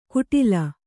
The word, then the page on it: ಗುಡಿಲ್: guḍil